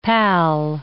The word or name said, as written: pal